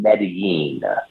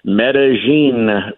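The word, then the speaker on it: Medellín: And real quick, so that I don't look like a country rube who's an idiot down here, I've been saying Medellin, like a Y, Medellin, Colombia. But you and a couple of other people have pronounced it differently. So what are you saying? – So remember one time you corrected me on Bowie versus Bowie, Maryland? I will correct on Medellin with a J, Colombia.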